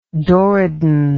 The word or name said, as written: doriden